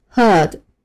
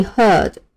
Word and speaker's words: herd: 第二个单词 herd。 H E R D herd，意思是动物群，成群的动物。注意一下这个词的词尾发音比较轻，不要发成 herd，要把中间的呃拉长，尾音呢要收短一些，轻一点。